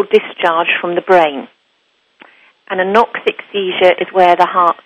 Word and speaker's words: anoxic: This, how do you pronounce it? Is it anoxia seizure? – Anoxic.